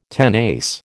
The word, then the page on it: tenace: American English